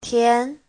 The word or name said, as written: tian2